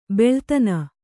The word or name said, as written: ಬಲ್ಲತನ